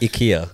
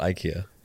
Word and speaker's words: IKEA: Technically, it's pronounced IKEA, not IKEA.